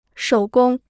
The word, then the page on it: 手工: shǒu gōng